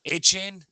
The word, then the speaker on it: Achane: Uh, Devon uh, Akane, is that how you say his name? – Achane.